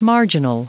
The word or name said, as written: marginal